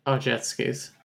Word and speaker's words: Aujeszky: I want to say it's Ajetskys. – Yeah, A-U-J-E-S-Z-K-Y. – Yeah, that sounds right. Ajetskys.